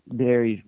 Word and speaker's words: Barrie: That's how you pronounce Barrie. It's Barrie, right? Or not Bede.